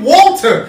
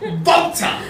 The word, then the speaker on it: WALTER: His name is Volta. Volta. – With a V. – It's with a W, but you have to pronounce it like you're from Austria. That's where he's from. Volta.